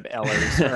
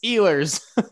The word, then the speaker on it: Ehlers: except for the pronunciation of Ehlers, or I mean Ehlers. It's Ehlers.